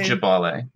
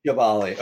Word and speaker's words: Gyebale: One, I'm going to butcher the pronunciation here, but it's, uh, I think it's Gaivale. – Gyebale. Mm-hmm. – Gyebale. Okay, sorry.